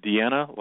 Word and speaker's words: Dina: And I just kept, it's spelled Diana, but I know that it's Deanna, like my friend Deanna Ballard out of Chicago, Illinois, who's listening tonight.